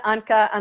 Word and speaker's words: Onkka: And my last name is O-N is in Nancy, K-K-A, O-N-K-K-A. It's Finnish. My family's from Finland.